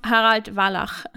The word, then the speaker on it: Harald Wallach: But the word Wallach is a German word. That's why I wasn't sure. But he's pronounced Harald Wallach.